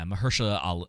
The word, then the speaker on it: Mahershala Ali: Yeah, Mahershala Ali.